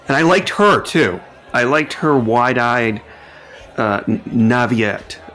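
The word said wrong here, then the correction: naïveté: No, how do you pronounce that? – Naivete. – Yeah, there you go.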